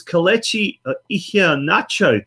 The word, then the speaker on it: Kelechi Iheanacho: And it was Kelechi Iheanacho.